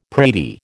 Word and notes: pratie: American English

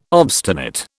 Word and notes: obstinate: British English

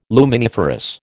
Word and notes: luminiferous: American English

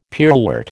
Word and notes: pearlwort: American English

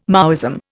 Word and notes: Maoism: Indian

